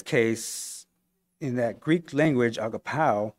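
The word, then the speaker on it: agapao (ἀγαπάω): In the original Greek word and the Greek translation, the word that's used in place of love is a Greek word pronounced agapao, agapao.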